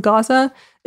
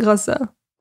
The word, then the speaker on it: Gaza: It does not deter them from trekking through the area and protesting in an attempt to slow aid trucks from entering Gaza. I'm noticing right now that I'm interchangeably using Gaza and Gaza. Gaza is just how you say it in Arabic.